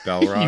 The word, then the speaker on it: Balrog: Do I say bell or ball? – It's ball, like basketball. – I thought I said ball. – You said bell. – Balrog.